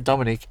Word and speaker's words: Dominic: It's definitely a silent K, no matter where you put it. You spell my name any way you like it, but you still pronounce it Dominic, and so the K is silent.